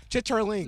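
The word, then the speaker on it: chitterlings: Wait, you don't know what chitlins are? – No. – First of all, chitlins are, it's a pig intestine. – First of all, they pronounce chitterling. – Listen, no, listen, spelled listen. Chitlin.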